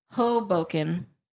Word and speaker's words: Hoboken: Hoboken. – Hoboken. – Mm-hmm. Emphasis on the ho.